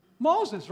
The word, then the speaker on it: Moses: How do you say Moses? – Moses. Моисей. – Moses? – Моисей. Моисей.